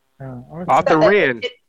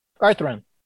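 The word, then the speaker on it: Autherine: Arthur Ann? – Back then, names were deep. – No, it's not spelled like Arthur Ann. – Arthurin. – Yeah. – Arthurin. Okay, like the female for Arthur. Arthurin.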